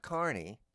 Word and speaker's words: Carney: Now, Art Carney, whose name is pronounced like the New Jersey town that's spelled differently, is very difficult for me to understand.